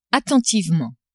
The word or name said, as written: attentivement